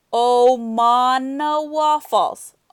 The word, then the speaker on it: Omanawa Falls: It's called, Omanawa Falls. Omanawa Falls.